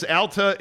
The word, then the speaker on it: Alta: Tom says, Alta is a city school ski resort. Ulta sells makeup. Pronounce it correctly.